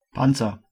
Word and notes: shield: /ʃiːld/; American English; [ʃiːld]

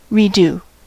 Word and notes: repasser: France; [ʁǝ.pa.se]

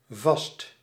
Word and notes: vast: /vɑst/; /vast/

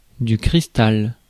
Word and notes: cristal: France; [kʁis.tal]

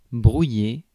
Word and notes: brouiller: France; /bʁu.je/

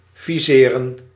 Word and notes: target: /ˈtɑrɡɪt/; [ˈtʰɑ˞ɡɪt]; /tɑːɡɪt/

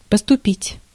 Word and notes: поступить: /pəstʊˈpʲitʲ/

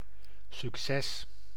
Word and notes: Erfolg: /ɛa̯ˈfɔlk/; /ɛrˈfɔlk/

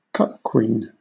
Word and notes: cuckquean: /ˈkʌk.kwiːn/

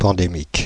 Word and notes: pandémique: [pɑ̃.de.mik]

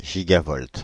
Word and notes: gigavolt: [ʒi.ga.vɔlt]

